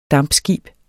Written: dampskib

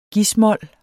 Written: gis-mol

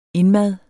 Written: indmad

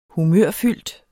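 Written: Humørfyldt

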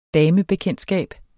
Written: DAMEBEKENDTSKAB

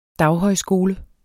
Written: daghøjskole

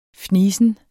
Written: fnisen

